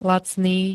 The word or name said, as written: lacný